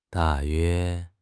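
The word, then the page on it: da4 yue1: tones 4, 1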